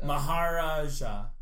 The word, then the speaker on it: Maharaja: Is he pronouncing it right? – Maharaja. Yeah. – It's Maharaja. That's it. Like, Maharaja.